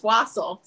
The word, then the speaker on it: Wasial: It's Wasial.